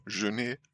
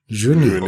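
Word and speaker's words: Jeunet: Können wir uns auf einigen? Genet wird ausgesprochen. Genet. – Genet. – Okay, okay, okay.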